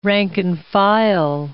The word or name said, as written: rank-and-file